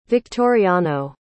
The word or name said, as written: victoriano